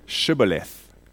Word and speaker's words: Shibboleth: And so the sentries would ask each person who wanted to cross the river to say the word Shibboleth. And the Ephraimites, who had no SH sound in their language, pronounced the word with an S at the end.